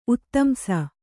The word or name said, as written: ಉತ್ತಂಸ